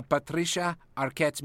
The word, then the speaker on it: Patricia Arquette: A Patricia Arquette's mediums. – Well done, Pepe. Somewhere Patricia Arquette has sensed that you have pronounced her name correctly.